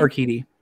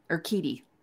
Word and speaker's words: Urquidy: Is that how you say his name? – Urquidy. – Urquidy.